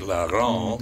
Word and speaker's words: Laurent: She pronounced it Laurent, mm-hmm. The French way.